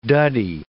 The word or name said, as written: duddie